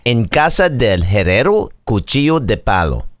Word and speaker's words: En casa del herrero cuchillo de palo: En casa del herrero cuchillo de palo. I think I said that right. – You did.